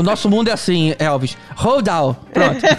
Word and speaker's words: Roald Dahl: baseadas nos livros do Rob Roald Dahl. Não, Roald Dahl. Tá vendo? Nem sempre eu sei pronunciar as coisas em inglês.